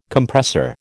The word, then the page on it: Compressor: American English